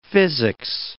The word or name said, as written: physics